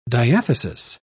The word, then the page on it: diathesis: British English